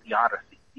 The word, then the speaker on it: theodicy: A theodicy, I actually believe it's pronounced theodicy, T-H-E-O-D-I-C-Y.